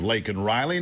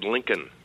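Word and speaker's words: Laken Riley: He finally said her name because he was goaded into saying it, but he gave no respect to the death of Lakin Riley, none. And I, by the way, I commend him. He said Lincoln. – Right. He can't even get the name right.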